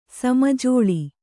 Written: ಸಮಜೋಳಿ

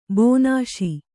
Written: ಬನಸಿ

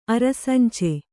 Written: ಅರಸಂಚೆ

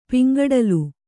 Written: ಪಿಂಗಡಲು

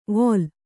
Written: ವೋಲ್

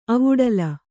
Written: ಅವುಡಲ

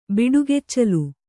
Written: ಬಿಡುಗೆಚ್ಚಲು

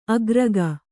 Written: ಆಕರಿಕೆ

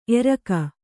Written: ಏರಿಕ್ಕು

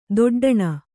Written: ದೊಡ್ಡಣ

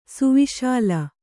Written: ಸುವಿಶಾಲ

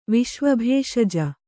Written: ವಿಶ್ವಭೇಷಜ

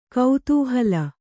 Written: ಕುತೂಹಲಿ

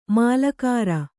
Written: ಮಾಲಗಾರ

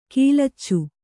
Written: ಗಾಲೀಚ

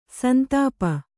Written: ಸಂತಾಪ

